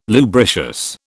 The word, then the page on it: lubricious: British English